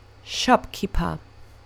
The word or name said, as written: shopkeeper